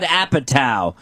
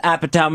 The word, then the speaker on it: Apatow: Also, on demand, This Is 40, the new movie from, I believe you pronounce this, Judd Apatow.